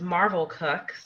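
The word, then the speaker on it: Marvel Cooke: and I actually learned that her name is pronounced Marvel Cook, Marvel.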